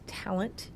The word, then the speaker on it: Tallent: There's a brand new book that came out called My Absolute Darling, and it's by Gabriel, I think it's pronounced Talent, T-A-L-L-E-N-T.